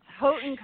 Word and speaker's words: Houghton: It's probably one of the most mispronounced colleges in the world, but it's Houghton College in Western New York.